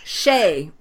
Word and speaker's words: Skjei: no, I was the same way, and then I realized I didn't know how to pronounce it, so I had to go find out how to do it. it's Shay.